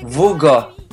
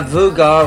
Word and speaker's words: våga: Alors, cette semaine, le mot suédois, ça va être Voga. Ça doit se dire comme ça, Voga, Voga. Ça s'écrit avec un A, mais avec un petit rond dessus. Et on a appris avec Måns Zelmerlöw que les A avec accent, ça se dit O. Donc là, c'est Voga, qui veut dire oser.